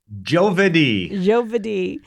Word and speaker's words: Giovedì: So we know our narrator as our young man, or as, okay, help me with the pronunciation for Italian for Thursday. I don't remember. – Giovedì, which is Giovedì.